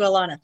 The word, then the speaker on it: Alana: Special shout out to Alana. Okay. – Alana rhymes with banana.